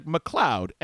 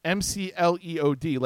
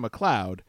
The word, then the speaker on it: McLeod: So it's spelled like McLeod, MCLEOD, like Gavin McLeod. – Sure. – But they keep calling her McLeod as if they all like they wrote it phonetically and they just couldn't, or they're just too stupid.